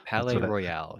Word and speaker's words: Palais Royale: But Palais Royale. – Yes. – I don't know how to pronounce their name, but like it's just Palais Royale.